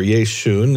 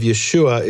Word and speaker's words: Yeshua: So he can't call him Yeshu or Yeshun. There's no s, mm-hmm. or there's no sh, it becomes s. And then the last letter of Yeshua is an ayin, mm-hmm. which most people have trouble pronouncing in the Western world. Mm-hmm. And in the Second Temple period, even many Jews had trouble pronouncing it. And so they dropped that final ayin, and Yeshua became Yeshu. Mm-hmm. And in Greek, Yeshu was Yesu.